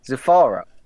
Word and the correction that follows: Zathura: How the hell are you... – Yeah. – Where are you getting the F from? Yeah, Zathura.